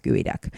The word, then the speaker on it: geoduck: So gooey duck is spelled G-E-O-D-U-C-K. Looks like geoduck, but it's pronounced gooey duck.